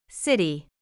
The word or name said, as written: city